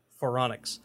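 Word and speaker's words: Phoronix: It only took me four tries to get it right. There's a lot of like silent letters in here and an R where I didn't expect there to be. I thought that'd be a T, and it's not. Foronix.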